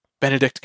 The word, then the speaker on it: Benedict: Started 2010, the uh, Bennett Benedict Cumberbatch.